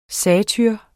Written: satyr

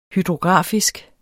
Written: hydrografisk

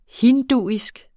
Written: hinduisk

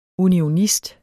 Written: unionist

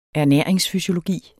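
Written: ERNÆRINGSFYSIOLOGI

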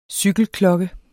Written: cykelklokke

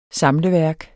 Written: samleværk

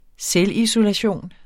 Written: selvisolation

